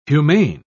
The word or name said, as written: humane